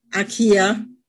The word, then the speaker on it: Akiya: I hope I pronounced that, not your name, but Akiya, the right way.